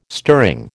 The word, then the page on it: stirring: American English